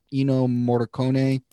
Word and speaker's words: Ennio Morricone: I like, you know, putting on John Williams radio or I always butcher his name, Ennio Morricone.